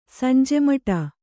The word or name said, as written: ಸಂಜೆಮಟ